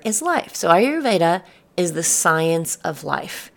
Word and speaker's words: Ayurveda: So I'm going to spell it for you because it looks completely different than what it sounds. Ayurveda is spelled A-Y-U-R-V-E-D-A.